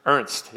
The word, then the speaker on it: Ernst: Ernst, yeah.